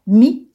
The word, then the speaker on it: Mye: Mi, ça s'écrit M-Y-E.